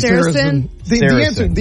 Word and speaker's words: Saracen: Saracen.